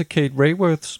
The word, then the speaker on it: Kate Raworth: Men så kan vi jo opfordre vores lyttere til at læse Kate Raworths bog, Donutøkonomien. Og nu har jeg set YouTube-videoer, det er sådan, man udtaler hendes navn. Så nu har vi hermed fået slået det fast.